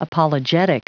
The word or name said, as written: apologetic